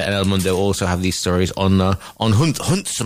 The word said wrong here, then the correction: Junts: Am I pronouncing it correctly? Junts, right.